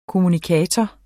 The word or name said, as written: kommunikator